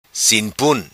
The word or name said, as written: Sin Pun